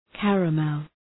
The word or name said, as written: caramel